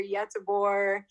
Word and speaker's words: Göteborg: Well, you can say Gothenburg or Göteborg. It's just like, but say Gothenburg, that's more Americanized.